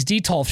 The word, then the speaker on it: Detolf: So they have these Detolf shelves. I think I'm saying it right. Detolf shelf. D-E-T-O-L-F.